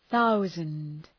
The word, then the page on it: thousand: ‘ɵaʋzənd